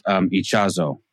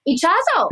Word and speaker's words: Ichazo: By the way, the guy's name should be pronounced Ichazo. – Ichazo. – Because I looked him up and he's Bolivian, so that's Spanish.